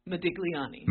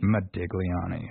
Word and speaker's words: Modigliani: Modigliani. – Modigliani.